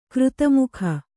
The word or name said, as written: ಕೀರ್ತಿಮುಖ